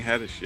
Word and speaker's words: Haddish: Yeah, Tiffany Haddish, yeah.